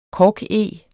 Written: korkeg

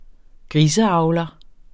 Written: griseavler

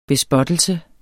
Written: bespottelse

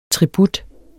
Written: tribut

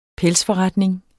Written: pelsforretning

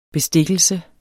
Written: bestikkelse

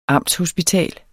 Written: amtshospital

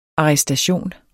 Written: arrestation

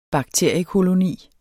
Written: bakteriekoloni

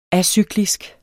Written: acyklisk

